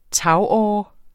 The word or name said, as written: tagåre